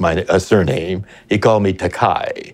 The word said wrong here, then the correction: Takei: but I corrected that. I said, my name is pronounced Takei, but I don't object to Kai, because that's a Japanese word that means expensive.